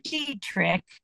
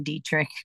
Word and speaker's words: Detrick: Well, Fort Detrick, D-E-T-R-I-C-K, in many of the audio versions, they don't say it right or they spell it as the German name Dietrich.